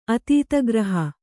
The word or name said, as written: ಅತೀತಗ್ರಹ